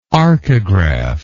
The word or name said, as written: arcograph